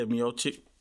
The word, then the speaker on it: Miocic: And forgive me because I'm going to butcher this man's name, but is it Miocic? – Darko Milicic. – Anyway. – Stipe Miocic? – Yeah, Miocic. – Okay, I was pretty close then.